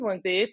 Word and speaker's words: Lindsey: But yes, my name is Lindsay, spelled with an E, not an A.